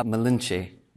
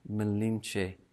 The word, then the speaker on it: Malinche: And on the flip side, the evil La Malinche. Malinche. Sorry.